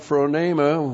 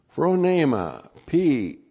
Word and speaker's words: phronema: And then we have phronema. We already had it in the first half of verse 6, and now it's repeated again. Phronema, P-H-R-O-N, long E-M-A, phronema.